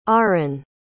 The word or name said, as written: ahren